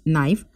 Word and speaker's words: knife: Learning to speak, pronounce simple words with silent words, knife. K is silent in the word knife, listen. T is silent, write.